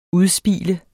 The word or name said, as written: udspil